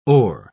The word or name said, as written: o'er